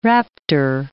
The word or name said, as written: raptor